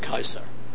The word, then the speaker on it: Caesar: that's because in Latin, the C is not a S, it's a K sound, okay? In other words, the Romans probably pronounce it Caesar, okay?